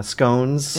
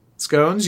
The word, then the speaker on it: scone: Yeah, scones. – Yeah, with tea. Scones. You said it correctly. It's not a scone. It's a scone.